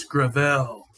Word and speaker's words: Gravel: And you did mispronounce his name, Eric, I think, the first time you met him. He's like, it's Gravel.